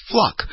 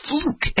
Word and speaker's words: Fluke: It's spelled Fluke, but people said her name was Fluck. And I'm going, seriously? Her name is Fluke. How do you get Fluck?